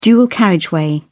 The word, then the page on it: dual carriageway: British English